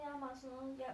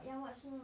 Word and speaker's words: 亚马孙: 在那个南美洲有一条河，爸爸上学时候呢，那河叫亚马逊河，现在你们改名叫亚马孙河了。电影。 – 现在好像也是从亚马孙亚亚马逊河呀。 – 哦，但是好像中央电视台发音就改成亚马孙河了，说这些字啊发音都改了。